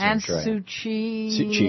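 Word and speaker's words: Aung San Suu Kyi: but they're saying, Aung Susie Khan. – That's right. – I might mispronounce it. – Aung Suu Kyi.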